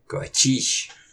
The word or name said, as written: Qacish!